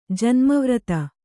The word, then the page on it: ಜನ್ಮವ್ರತ: Kannada